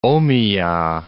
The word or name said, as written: Omiya